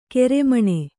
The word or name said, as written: ಕಾರ್ಮಣ